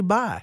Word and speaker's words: Bai: I drink their coconut water sometime, and I'm sitting there going, I never knew how to say it. It's actually Bai.